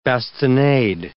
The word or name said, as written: bastinade